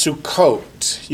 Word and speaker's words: Sukkot: You can spell it S-U-K-K-O-T-H or S-U-C-C-O-T-H. Or you can spell it Fred. So long as you look at it and say Sukkot. So that's how you transliterate Hebrew letters, is whatever makes you say Sukkot.